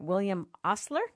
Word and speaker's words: William Osler: I don't know if I'm saying his name right. William Osler, is that right? – You got it.